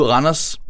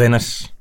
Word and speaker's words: Randers: Mod Randers. – Nej, det er Randers.